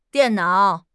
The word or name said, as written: dian4 nao3